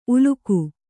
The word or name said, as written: ಉಲಿಗ